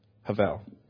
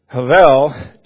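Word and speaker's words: hebel: So it's H-E-B-E-L, and it's pronounced havel. Havel, it occurs in this book, 12 chapters, 38 different times.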